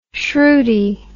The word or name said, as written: shrewdie